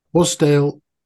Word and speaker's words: Wasdale: Am I saying that right? – Right. Can I just interject here? – Yes. – You've, d- you've done wonderfully. Wasdale, Westwater, you've done brilliantly.